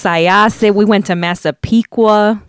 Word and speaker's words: Syosset: George Lutz was in Syosset running some errands. Did I say that right? – Syosset, yeah.